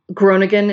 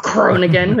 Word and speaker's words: Groningen: Groningen in the Netherlands, which I'm not pronouncing right because you're supposed to do the guttural R, Groningen, which I can never do.